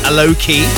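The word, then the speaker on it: Alok: So as we discovered the other day, the correct way to pronounce his name is Aloki.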